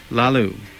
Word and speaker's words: Lalu: L-A-L-U. – Lalu.